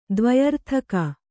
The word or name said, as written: ದ್ವ್ಯರ್ಥಕ